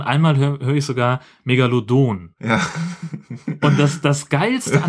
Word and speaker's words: Megalodon: Nämlich einmal so, wie es richtig ist, Megalodon.